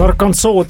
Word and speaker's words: Arkansas: Hästi pakutud, Arkansas taas kord õige vastus. Eksitus paljude jaoks tuleb tõenäoliselt sellest, et sarnase kirjapildiga Kansas hääldub ka lõpuosas tähtähelt samamoodi. Ja teine osariikis muide S häälikult lõpus välja öelda on Illinois, kuid seal taolist eraldi regulatsiooni kunagi vastu võetud ei ole.